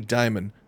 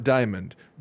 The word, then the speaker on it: Dimon: And two, it's pronounced Jamie Dimon, not Diamond. Don't walk around thinking it's Dimon and be like me.